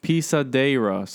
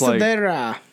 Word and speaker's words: Pisadeira: The creature is called Pisadeira. So it's almost Pisadeira. – Like pesadilla is nightmare in Spanish, and it comes into people's house, into a person's house, and tramples on the chest of those who are asleep.